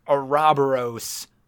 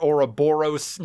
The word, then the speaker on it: Ouroboros: I this is a fucking weird, very specific to us cut, but the final boss of the original Bravely Default is pronounced Ouroboros, but we realized we've been calling it Ouroboros now, and I don't know what words mean, really, at this point anymore.